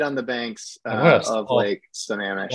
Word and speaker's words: Sammamish: My understanding is it's in Sammamish, Washington.